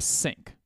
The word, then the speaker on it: Cenk: I think you pronounce his name Sink. It is C E N K.